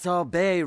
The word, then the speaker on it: sorbet: blood orange sorbet. – Gillian, it's pronounced sorbet, remember? – Oh, yes, sorry. Sorbet. Like Corbet? – That's it.